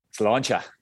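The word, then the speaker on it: sláinte: How do you say it properly? – Slauncher. – Cheers in Irish. Say it again. – Slauncher. – Slauncher. That's cheers in Irish.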